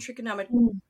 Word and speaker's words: trichotillomania: So while we're on Tusha, we would love to hear about your research for the PTSD and trichotillomania.